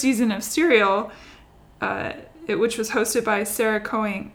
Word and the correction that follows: Koenig: Koenig, ah, Koning. I can't say her name, ah, Koenig, there it is.